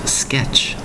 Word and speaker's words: sketch: S-K-E-T-C-H. Sketch. Now, the T in that word is very slightly sounded. Barely heard, but you have to be able to hear it slightly. Sketch. Notice the T is almost totally quiet, but it is there. Again, the word is sketch. S-K-E-T-C-H.